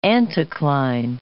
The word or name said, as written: anticline